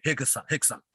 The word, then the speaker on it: Rickson: Yeah, spelled Rickson, but yes. In Portugal, you have to, you know, pronounce it like Hickson.